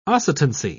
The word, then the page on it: oscitancy: British English